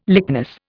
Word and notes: lychnis: Indian

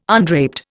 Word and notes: undraped: Indian

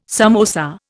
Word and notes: samosa: Indian

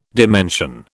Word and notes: dimension: British English

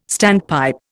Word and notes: standpipe: Indian

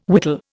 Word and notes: whittle: Indian

